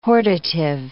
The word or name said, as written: hortative